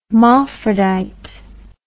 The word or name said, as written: mophrodite